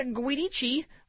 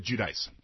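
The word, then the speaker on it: Giudice: The Chicago family says Teresa Guidici. – On the show, they pronounce it Judice.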